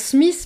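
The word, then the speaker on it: Psmith: En fait, ça se prononce Smith, mais il y a un P qui ne se dit pas.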